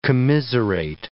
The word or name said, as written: commiserate